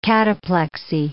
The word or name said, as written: cataplexy